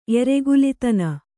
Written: ಎರೆಗುಲಿತನ

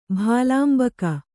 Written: ಭಾಲಾಂಬಕ

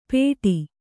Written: ಪೇಢಿ

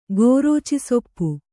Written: ಗೋರೋಚಿಸೊಪ್ಪು

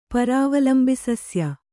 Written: ಪರಾವಲಂಬಿಸಸ್ಯ

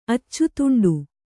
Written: ಅಚ್ಚುತುಂಡು

